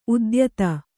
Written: ಉದ್ಯೋತ

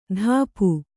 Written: ಧಾಪು

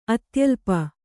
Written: ಅತ್ಯಲ್ಪ

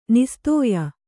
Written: ನಾಸತ್ಯ

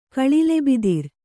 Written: ಕಳಿಲೆಬಿದಿರ್

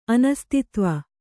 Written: ಅನಸ್ತಿತ್ವ

